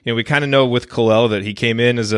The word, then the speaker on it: Kel'el: and is that the proper pronunciation? – Kel'el, somewhere in that range, it kind of floats back and forth, but you got it.